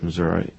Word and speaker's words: Missouri: The right way to pronounce Missouri is Missouri, as you can tell, as if it's spelled M-I-S-S-O-U-R-A.